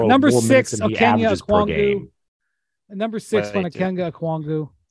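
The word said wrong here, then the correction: Onyeka Okongwu: This is the worst pronunciation of his name of all time. – Onyeka Okwongwu. – Big O.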